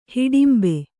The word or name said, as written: ಹಿಡಿಂಬೆ